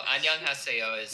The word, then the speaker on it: 안녕하세요: I was going to say, 안녕하세요, but I'm pretty sure you could correct my pronunciation. – Yeah. No, 안녕하세요 is how you say it, And then there's, like, you know, 친구하자, like, let's go be friends, right?